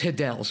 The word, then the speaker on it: Hadel: By the way, it's Hadel, so it's Hadel and Quaysack.